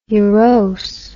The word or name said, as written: ulose